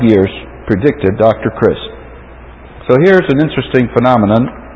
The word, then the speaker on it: Christ: Dr. Christ, which rhymes with grist, said, Dr. Grist is head of the San Jose State University Women's Studies program.